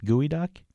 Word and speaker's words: geoduck: Can you remind me why it's spelled that way if it's pronounced gooey duck? – Well, apparently it was the Salish peoples called it gooey duck, and it was spelled G-O-E-D-U-C-K. But G-O-E looks sort of trying to sound out how it sounded like in Salish language.